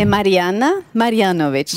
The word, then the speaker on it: Marijana Marjanović: Mari, como é que pronuncia seu nome? – É Marijana Marjanović.